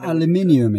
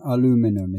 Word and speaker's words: aluminium: aluminium is how I would say it. Yes. Who's saying aluminum? Is that Americans? – Yes, that's American, definitely.